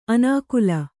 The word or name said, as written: ಅನಾಕುಲ